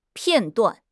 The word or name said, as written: 片断